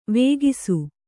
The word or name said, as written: ವೇಗಿಸು